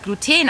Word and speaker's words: Gluten: Also, ich habe erfahren, dass beide Betonungen entweder auf dem U oder auf dem E richtig sind. Meistens betont man das E. Und das kommt daher, weil Gluten ein chemisches Gemisch aus Eiweißteilchen ist und chemische Verbindungen häufig auf der letzten Silbe betont werden.